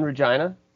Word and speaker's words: Regina: Yeah, I'm in Regina. Mm-hmm. The city that rhymes with fun.